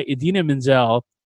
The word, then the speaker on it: Idina Menzel: And he's like, when the song was performed by Idina Menzel, pronounced exactly how it's spelt, I fucking laughed because that was a dig at Travolta.